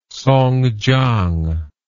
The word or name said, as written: Songjiang